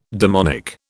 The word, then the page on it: demonic: British English